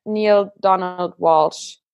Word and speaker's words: Neale Donald Walsch: von äh, Neil Donald Walsh. Mhm. Ich glaube, so spricht man ihn aus.